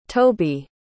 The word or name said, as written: tobie